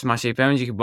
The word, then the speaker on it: Maciej Pędzich: So my real name is Maciej Pędzich, but if you're not from Poland, there's no way you're pronouncing that correctly, so you can just call me Mac.